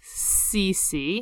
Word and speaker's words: Circe: This island is run by Circe, otherwise known as Circe. – Yes. Oh, so like I think in Greek it's actually you're supposed to pronounce like all the C's like K's.